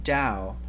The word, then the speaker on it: Tao: Michael actually prefers not to use the word God either, using the Eastern term Tao, pronounced with like Tao with a hard D, and this means the way.